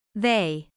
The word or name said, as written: they